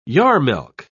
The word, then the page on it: yarmelke: British English